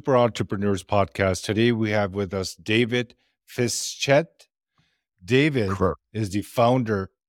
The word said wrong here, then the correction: Fischette: Almost. It's Fischette, like a small Fischette. Yeah. Perfect. David Fischette.